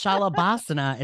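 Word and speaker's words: Shalabhasana: It's Shalabasana. It's with a B.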